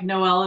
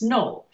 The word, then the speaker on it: Noel: And it reads like Noel and Leon, but Noel is actually pronounced like Joel. It's Noel. And I have to train myself to say it that way.